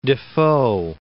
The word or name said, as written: Defoe